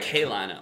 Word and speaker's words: Carolina: Okay. Like, line. It's kind of like K-Lina, almost. Like, yeah. K-Lina.